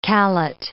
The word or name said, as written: callet